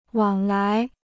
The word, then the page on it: 往来: wǎng lái